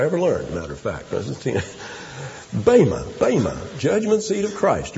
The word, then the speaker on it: bema: It's called, the word is pronounced bema.